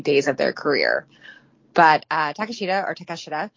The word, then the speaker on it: Takeshita: On AEW, they say Takeshita.